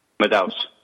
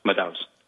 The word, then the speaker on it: Madaus: Is that how you pronounce your last name? – Madaus. Madaus.